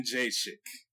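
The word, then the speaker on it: Jędrzejczyk: Let me know if I get it right. Joanna. Jajochik. – Yin Jajochik.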